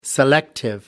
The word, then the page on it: selective: American English